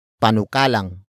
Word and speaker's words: panukalang: First, we have panukalang. Panukalang. Panukalang. Panukalang is spelled as P-A-N-U-K-A. L-A-N-G.